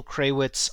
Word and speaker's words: Krawisz: Look up my show, Bitcoin Stuff, on YouTube, and I'm Daniel Krawitz on Twitter.